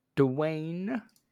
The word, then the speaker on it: Dwayne: The G was a little hard there. – Dwayne Dwayne Johnson. – The G is silent. – Take what you need from this. So, Dwayne The Rock Johnson.